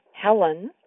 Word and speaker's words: Helen: My first name, Helen, spelt H-E-L-E-N, is pronounced Hel-N, with the emphasis on the first syllable or first beat of the name.